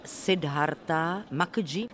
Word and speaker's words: Siddhartha Mukherjee: Řekněte mi, pane profesore, kdo je Siddhartha Mukherjee, autor tohoto bestselleru? – Ano, říkáte to správně, i když se to obtížně vyslovuje, protože to jméno je indické.